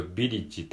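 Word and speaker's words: village: カ、カレッジ、ビレッジ、ビレッジ、カレッジ、カリッジとビリッジって感じですね。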